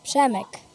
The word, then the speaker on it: Przemek: Say your name for me again. – Przemek. – Przemek. So Przemek says Przemek it's migraines.